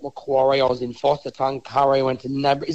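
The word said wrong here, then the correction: Nabiac: Is it Nabarick? – Nabiak.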